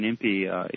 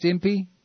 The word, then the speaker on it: Impe: Is Jack Van Impe, is it pronounced Impe? – I believe it's Jack Van Impe.